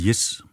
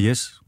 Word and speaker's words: Jes: Ja, og hvis jeg lige skal blive i Adrians linguistiske fodspor, så hedder jeg faktisk Jes og ikke Jes. Men jeg bliver altid kaldt Jes. Og det er fint nok. – Hvad for noget? – Mit fornavn udtales Jes. – I kontrast til Jes. Kan du høre forskel?